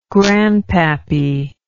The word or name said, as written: grandpappy